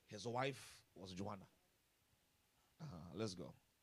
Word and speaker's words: Joanna: Jonah. – That's how you pronounce it here. – Isn't that? Oh, Joanna? – Oh, okay. Because I was like, maybe that's American pronunciation. Because if you say Jonah, there, then it's like, I didn't see the extra. – Jonah, the wife of Chuza, that means like their gays. Okay, so Joanna, right? – Joanna. – Okay, that's it's the same pronunciation here. Okay.